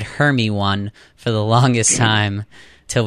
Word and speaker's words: Hermione: Do you remember how you first pronounced Hermione's name when you read it? – Hermione.